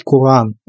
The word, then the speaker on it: Коран: И я ее объяснял, реально вот человеку, это летом было, в конце лета, я объяснял, что правильно как бы произносить Куран, но...